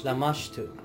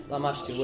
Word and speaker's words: Lamashtu: Lamashtu. – There you go. Pronunciation is harsh. Lamashtu, which...